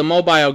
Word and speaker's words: Mobile: So you also have recorded numerous songs of your mother's, including one that is a favorite of mine called The Mobile Goat. – The Mobile Goat. Now, pronounce it right. Not the mobile. Mobile is Mobile, Alabama. Mobile is a place in Newfoundland.